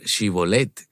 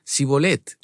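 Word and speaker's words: Shibolet (שיבולת): Parece que aquellos asentados en la zona de Efraín no sabían decir Shibolet, sino que decían Sibolet.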